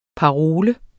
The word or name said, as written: parole